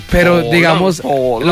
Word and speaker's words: Haaland: Pero las mujeres, estoy notando, es que extrañan del mundial no los partidos, sino a Haaland. O bueno, ¿cómo se dice, Cristian? – Haaland.